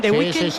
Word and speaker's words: The Weeknd: Miguel Ángel, lo he dicho bien. The Weeknd. – Sí. – Perfecto.